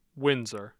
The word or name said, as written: Windsor